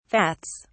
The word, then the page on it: vats: British English